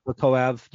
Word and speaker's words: Bekoyev: Bekoyev. – Do you know that for sure? – I don't, but that's usually how you say it. You put the emphasis on the syllable right before the ev.